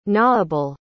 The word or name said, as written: gnawable